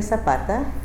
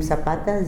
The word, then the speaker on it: Zapata: My name is Clemencia Zapata. That's C-L-E-M-E-N-C-I-A. Last name Zapata, Z-A-P-A-T-A.